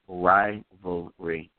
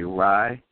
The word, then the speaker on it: rivalry: See, we all get messed up because we're trying to say that word too quick. You got to just say rivalry. You just got to get all the syllables in.